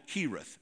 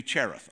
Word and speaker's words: Cherith: It's spelled C-H-E-R-I-T-H. And it looks like Cherith, like cherish. But I've always called it Kirith.